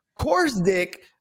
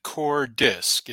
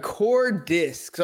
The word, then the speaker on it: CoreDISC: I usually pronounce it CoreDISC. It's for coordinated discovery. So it's the first syllable of each of those words. – All right. CoreDISC.